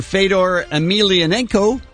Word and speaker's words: Fedor Emelianenko: MMA heavyweight fighter Fedor Emelianenko is 34.